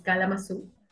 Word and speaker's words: Cala Mazú: My real name is Irene, but my artistic name is Kalamazoo, which comes from a little town in Michigan, in the United States, called exactly like that, Kalamazoo, but it's spelled differently.